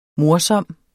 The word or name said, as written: morsom